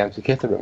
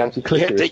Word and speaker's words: Antikythera: of of yeah of um, who was a firm believer in the alien hypothesis of the antikythera mechanism, but mm-hmm. suffered like you from a slight problem with pronunciation, called it you the antikythera. It called it the anti-clitoris mechanism.